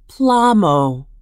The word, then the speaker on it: plamo: The Mi'kmaq word for salmon is plamo, which is pronounced plamo.